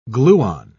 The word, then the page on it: gluon: British English